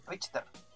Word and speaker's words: Richter: o Ricker, no sé cómo se pronuncia en verdad el nombre culiado. Eh, ah, no, es Richter.